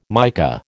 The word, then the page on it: mica: American English